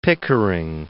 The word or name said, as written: Pickering